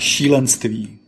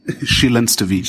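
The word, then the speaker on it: šílenství: Und auf Tschechisch, weil das kann wieder keiner aussprechen. – Oh ja, Schilanstwi. Schilanstwi. Schilanstwi, okay. mit einem langen I hinten.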